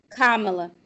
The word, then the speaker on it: Kamala: Kamala? – Kamala.